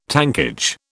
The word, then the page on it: tankage: British English